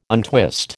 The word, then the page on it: untwist: American English